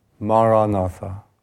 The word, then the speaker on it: Maranatha: And the word I recommend is Maranatha. Four syllables, Ma, Ra, Na, Tha.